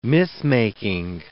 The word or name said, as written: mythmaking